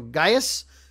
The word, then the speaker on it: Gaius: His name is, I believe it's pronounced Gaius Charles, G-A-I-U-S or Gaius.